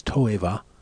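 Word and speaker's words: toeva (תועבה): In Hebrew, it's toeva, one word, but it can be broken into two syllables.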